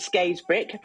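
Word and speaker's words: Scarisbrick: So thank you for pronouncing it Skays Brick, because that's how it should be pronounced, even though it's spelled Skays Brick.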